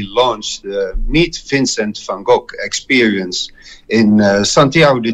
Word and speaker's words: van Gogh: Hola Rodrigo, soy Willem, Willem van Gogh, como se pronuncia en holandés.